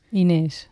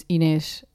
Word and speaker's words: Inês: The pronunciation is Inês. Like the S has the sound of sh. – So I-N-E-S.